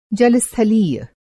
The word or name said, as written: जलस्थलीय